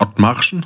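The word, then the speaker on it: Othmarschen: eins in Hamburg Ottmarschen, richtig ausgesprochen? Ja, richtig ausgesprochen.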